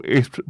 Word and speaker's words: Esprit: His singer-songwriter and session solo bass player, Carol Esprit Bridgman, and I'm hoping I didn't pronounce Esprit wrong, E-S-P-R-I-T, performing live in the studio.